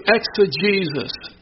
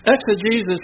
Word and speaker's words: exegesis: Exegesis is spelled E-X-E-G-E-S-I-S. The last part sounds like Jesus, but it's not spelled that way. It's not talking about Jesus, but it's called exegesis.